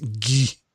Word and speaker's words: Guy: Yeah, Guy. Exactly. – And it's pronounced the exact same way unless you're in France and it's ghee.